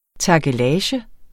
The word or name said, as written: takkelage